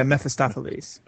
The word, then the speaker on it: Mephistopheles: Oh, yeah, Mephistopheles.